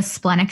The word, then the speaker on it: splenic: How do you pronounce that? The what center? – Splenic. – Splenic. – Like the spleen.